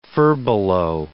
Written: furbelow

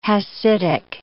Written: Hassidic

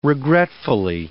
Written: regretfully

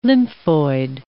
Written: lymphoid

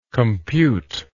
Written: computist

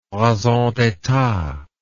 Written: raison d'etat

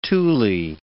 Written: tule

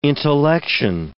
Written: intellection

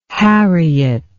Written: harriet

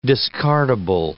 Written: discardable

